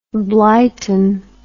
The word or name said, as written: blyton